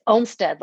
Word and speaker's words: Omstead: Almost. You're not the first one. It's okay. It's Omstead. Like Omstead.